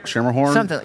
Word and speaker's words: Schermerhorn: If I'm saying it right, the Schermerhorn? – Schermerhorn. – It's like the word entrepreneur.